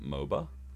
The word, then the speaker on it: MOBA: Is it MOBA? What's League of Legends? – MOBA, yeah.